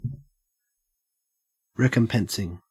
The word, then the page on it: recompensing: Australian English